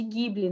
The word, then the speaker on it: Ghibli: É, e além disso, Ghibli é uma palavra que ela é de origem italiana. Né? A nossa pronúncia, a pronúncia italiana e até a nossa também, a gente chama ele de Ghibli, né? Por causa desse Hzinho.